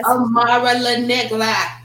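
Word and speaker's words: Amara La Negra: Amara LaNigla.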